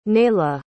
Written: naila

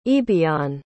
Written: aoibheann

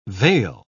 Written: vail

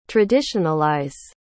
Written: traditionalize

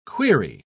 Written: quaere

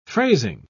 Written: phrasing

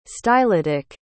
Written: stylitic

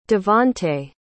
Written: devonte

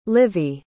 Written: livvy

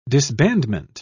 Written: disbandment

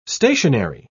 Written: stationery

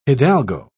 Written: hidalgo

